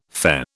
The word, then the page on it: Fe: British English